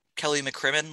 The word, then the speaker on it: McCrimmon: And well, frankly, and if you're really, really looking to trade for Jack Eichel and you're Kelly McCrimmon, let's say.